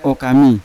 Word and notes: okami: Yawa

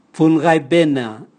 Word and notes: vungaibena: Raga

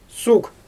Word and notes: suɣ: Xyzyl

